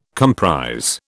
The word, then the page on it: comprise: British English